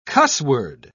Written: cussword

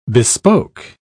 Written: bespoke

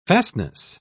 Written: fastness

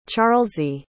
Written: charlsie

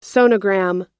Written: sonogram

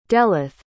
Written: delyth

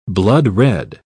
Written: bloodred

